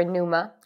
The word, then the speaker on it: Numa: We worked for Numa and they raised $59 million in their Series C last week.